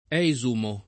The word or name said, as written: esumare